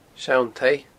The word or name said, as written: Seann Taigh